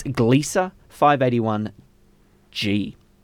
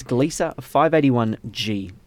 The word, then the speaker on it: Gliese 581g: It's Gliese 581g. Now, it actually isn't pronounced, it isn't spelt anything like that, but it's Gliese 581g.